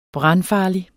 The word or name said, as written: brandfarlig